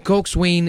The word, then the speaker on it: coxswain: Ang pagbasa po ng cox ay coxswain, pero pag pronounce po nito ay Coxswain.